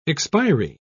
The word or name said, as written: expiry